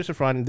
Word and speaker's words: Safran: I wanted to say, I'm trying to see if I pronounce his name correctly. Peter Safran. – Yes, Peter Safran.